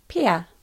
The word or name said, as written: Pea